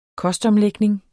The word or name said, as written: kostomlægning